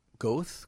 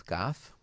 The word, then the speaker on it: Goethe: G-O-E-T-H-E. – Goethe? Goth? – Nope. – What is it? – Goethe. – Goethe. – Really? Look yep. at you. – Yeah. – Okay, and what does that mean? – Who knows? It's named after Johann. I just said, like, I just messed it up myself. Johann Wolfgang von Goethe. – Ooh. – All right. That's why where it comes from, but it's pronounced Goethe, even though it's G-O-E-T-H-E. It's German, obviously, pronunciation.